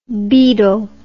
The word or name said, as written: bedel